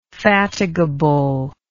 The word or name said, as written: fatiguability